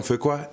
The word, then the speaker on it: Fuqua: Antoine Fuqua, it's lovely to meet you.